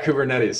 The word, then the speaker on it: Kubernetes: And I'm going to expose my ignorance here, but one of the ones you put on LinkedIn was Kuber Kuber something, and Kubernetes. yeah, Kubernetes.